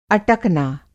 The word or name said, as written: अटकना